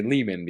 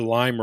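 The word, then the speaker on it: Leman: So you after that, you have to say Lehman because that's how it's pronounced. So I prefer Lime Russ myself.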